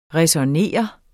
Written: ræsonnere

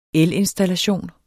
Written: elinstallation

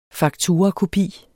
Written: fakturakopi

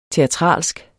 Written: teatralsk

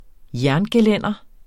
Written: jerngelænder

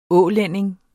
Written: ålænding

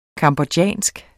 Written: cambodjansk

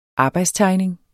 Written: arbejdstegning

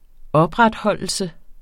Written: opretholdelse

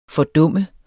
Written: fordum

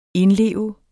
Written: indleve

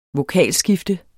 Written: vokalskifte

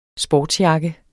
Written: sportsjakke

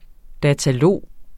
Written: datalog

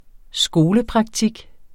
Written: skolepraktik

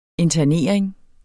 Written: INTERNERINGEN

